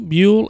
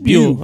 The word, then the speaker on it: Buhl: Shannon Pierce is a freshman out of, you say, is that Buell, Alabama? B-U-H-L, Buell, Alabama? Okay. – That's a new one on me. So I'm going to let them throw stones at you for mispronouncing because I don't know. – No, I said Buell. I got it right the first time.